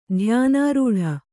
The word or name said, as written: ಧ್ಯಾನಾರೂಢೆ